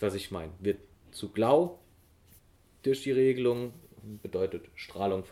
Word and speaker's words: glaw: GLAW geschrieben, wird aber ähm, als Glau ausgesprochen, bedeutet Strahlung.